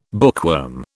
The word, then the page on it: bookworm: British English